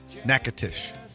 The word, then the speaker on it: Natchitoches: And it doesn't have how to pronounce it. Natchitoches. That's not how it's spelled. Not even close. – Hoping by the end of the weekend he'll have Natchitoches.